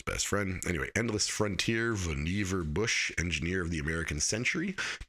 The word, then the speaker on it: Vannevar: So now I'm really reading Endless Frontier, Vannevar Bush, that's how you pronounce his name, Vannevar Bush. I always thought it was Vannevar Bush, and so did everybody else in his life, apparently, but it's Vannevar Bush.